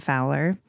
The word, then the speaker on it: Fowler: So F-R-O, like Fro, and then Man, M-A-N. Fowler is F-O-W-L-E-R.